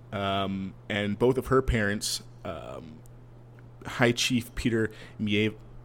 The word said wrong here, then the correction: Maivia: I always mispronounce this. – Maivia? – Maivia.